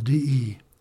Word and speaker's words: de: But remember, in French, it's pronounced de, kind of a, you know, like de, you know.